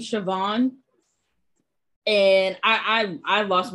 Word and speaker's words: Siobhan: It's Irish. That's why. Yeah. So it's like Siobhan, but it's p- pronounced Siobhan.